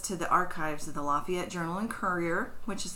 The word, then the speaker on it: Lafayette: I also want to point out, around the world, most people pronounce the name of this city as Lafayette. But in Indiana, we call it Lafayette. – Mm-hmm. – My grandfather even butchered it worse, and he said Lafayette. So I'm not mispronouncing it when I say Lafayette. It's just Indiana speak.